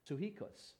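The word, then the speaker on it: psuchikos (ψυχικός): The Greek word is actually pronounced psuchikos, and it actually means soulish.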